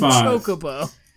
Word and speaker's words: Chocobo: He's a cockabo. – That's where the term cuck comes from. It derives from Final Fantasy V. – Chocobo. – Mispronunciation.